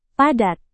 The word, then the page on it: padat: Indonesian